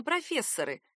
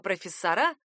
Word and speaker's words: профессора: Желая придать ему большую литературность, говорящие меняют ударение на «профессоры» и, сами того не зная, совершают ошибку. «Профессоры» — устаревший вариант, так говорили в XIX веке, а «профессора» — современный.